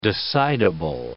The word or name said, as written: decidable